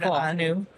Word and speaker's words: ANU: Well, all this time, I thought that it was the initials of something because it's spelled in all caps. – Right. – And so it's the Hebrew word, ANU.